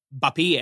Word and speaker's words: Mbappé: Han sitter i studion, tar emot och korrigerar deras uttal och säger då, Mbappé är det korrekta uttalet, eller något liknande.